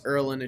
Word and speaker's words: Ierlan: And I was like, oh, it's Ierlan. It's just like the I's not there.